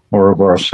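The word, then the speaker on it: Ouroboros: but that uburos, is that correct pronunciation? – Yeah, uburos. Yeah.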